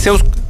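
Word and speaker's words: Zeuz: Como lo puse así con, con esa pronunciación porque en verdad es con doble Z. Z-E-U-Z. E Zeus. No, sí, en la, en la, está bien en la mención, Es Zeus. Es Zeus.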